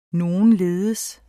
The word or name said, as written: nogenledes